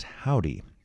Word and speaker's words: HOVVDY: They call themselves Indie Pop, and the name of the band is pronounced Howdy, but it's spelled with 2 V's instead of a W, so go figure. H-O-V-V-D-Y, pronounced Howdy.